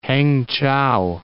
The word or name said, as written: Hangchow